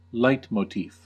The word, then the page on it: leitmotif: American English